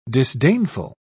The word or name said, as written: disdainful